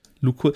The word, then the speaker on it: Lukull: Ähm, schreibt man L-U-K-U-L-L. Luko.